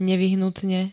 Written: nevyhnutne

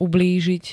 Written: ublížiť

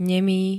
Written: nemý

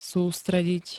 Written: sústrediť

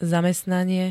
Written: zamestnanie

